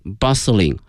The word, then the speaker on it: bustling: 但是有一点哈，这里面这个 t，这个字母 t 是不发音的，叫 bustling 啊，这是它发音上的一个细节哈，希望同学们注意一下 bustling street。